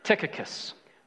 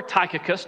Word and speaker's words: Tychicus: it was a man by the name of Tychicus, or Tychicus, depending upon our pronunciation.